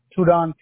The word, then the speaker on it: Tudon: So Luis, your first name, do I spell that L-U-I-S? – Correct. – And your last name? – Tudon, T-U-D-O-N. – T-U-D-O-N.